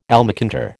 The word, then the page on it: almacantar: American English